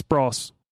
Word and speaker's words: Bros: Bros is pronounced like Ross. So it's Bros. – Yeah. – It's pronounced like Ross. Though bros sounds so much better.